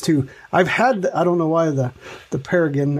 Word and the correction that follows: ptarmigan: It's ptarmigan. I don't even think you say the P. I think it's just ptarmigan.